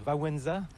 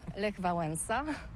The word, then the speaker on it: Wałęsa: Et maintenant, alors toi, tu le prononces à la française, mais moi, je vais le prononcer à la polonaise. – À la polonaise, okay. – Donc, je vais dire Wałęsa. – Lech Wałęsa.